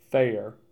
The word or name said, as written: Thayer